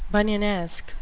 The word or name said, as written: bunyanesque